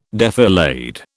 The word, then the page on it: defilade: British English